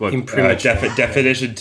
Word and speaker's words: imprimatur: Um, one of them is definitely just imprimatur, with a, a schwa at the end, which is just a short vowel sound. So imprimatur. Um, one at the end has an extra sound before the schwa. Maybe that me- that means imprimatur is also um, acceptable.